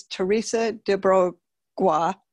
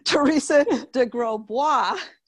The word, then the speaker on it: Teresa de Grosbois: Her name is Teresa de Grosbois. I said that wrong. Teresa de Grosbois. Hey, there you go.